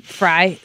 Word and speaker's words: Fry: My maiden name was an easy to pronounce name, Fry. It does look like Frey to many people.